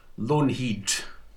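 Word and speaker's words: Loanhead: Yes, I was born in Edinburgh in 1969 and was brought up just south of Edinburgh in a mining village called Lonehead, pronounced Lonehead, in that neck of the woods in Midlothian.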